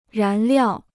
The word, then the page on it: 燃料: rán liào